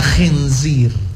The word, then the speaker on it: khinzir: Kemudian yang berikutnya pada kata khinzir, khi. Coba Ibu bilang khi. Khinzir. – Khinzir.